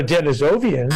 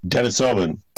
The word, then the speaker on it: Denisovan: It's not a Denisovian, is it? What is it? – Denisovan.